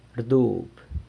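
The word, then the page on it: rdub: San Lucas Quiaviní Zapotec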